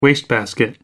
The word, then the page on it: wastebasket: American English